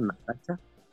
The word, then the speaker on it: Natacha: Y pues no, no, no termina de jalar muy bien, pero el arte de Natacha, Natacha, y, no estoy pronunciando mal la, la, así se llama Natacha.